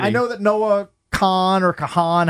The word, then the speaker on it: Kahan: They just call him Kahan. – No, that's how you say it.